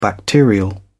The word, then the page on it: bacterial: British English